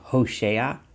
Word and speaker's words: Hoshea: I'm going to call him Hoshea, as he is pronounced in Hebrew, Hevav Shin Ayin.